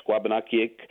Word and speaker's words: Wabanakiik: And the version that we're using right now, Wabanaki, is really more the anglicized version, although some of the tribal dialects do pronounce it more closer to that. From what I've been told and taught, you know, by folks like Roger Paul, Wabanakiik is more the term we would use in Passamaquoddy.